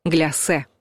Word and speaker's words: гляссе: С ударением тоже стало все однозначно. Оно падает на последний слог. И произносят слово твердо «гляссе».